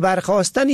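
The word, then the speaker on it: برخاستن: این دو کلمه خواستن و برخواستن یک گونه تلفظ می شود، اما در نوشتن و معنا با هم متفاوتند.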